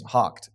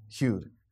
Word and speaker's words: houghed: So H-O-U-G-H-E-D is hocked. It's not hewed or hoed, however you'd like to say it, however we think we'd pronounce it.